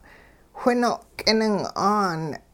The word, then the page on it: whino' k'ining'aan: Hupa